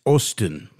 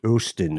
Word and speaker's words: Osten: Is it with an O or with an, it's probably with an O, isn't it? – Osten, maybe? – It's Osten. – Osten, there we go.